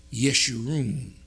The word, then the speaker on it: Jeshurun: This word, or this name, Jeshurun, in the English is pronounced Yeshurun in the Hebrew tongue.